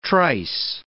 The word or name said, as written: trice